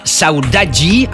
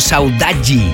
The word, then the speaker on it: Saudade: Pero sí lo hace sin duda esta que empieza a sonar ahora, que es de una formación que se llama Saudaji, aunque se escribe Saudade. Nos indican en la hoja de promoción que lo tenemos que pronunciar como Saudaji